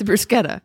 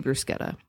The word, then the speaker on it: bruschetta: I don't know why people can't wrap their minds around that, like why they can't say the hard sound. No one says zucchini. – Is it spelled the same? – Yes. Oh. C-H-I. Well, C-H, yeah, E. But anyway, that's a huge pet peeve of mine is bruschetta. Please, all of our listeners, do me a favor and say bruschetta.